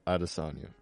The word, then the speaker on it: Adesanya: Can you pronounce that correctly for me? – Israel Adesanya. – Adesanya.